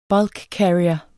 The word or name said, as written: bulkcarrier